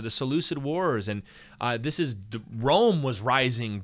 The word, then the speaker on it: Seleucid: Actually, it should be pronounced Seleucid, but we're not going to be that lame. We're just going to call them the Seleucids. There's always a hard... When you see C in Greek, it's almost always a hard C, by the way.